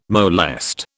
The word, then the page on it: molest: British English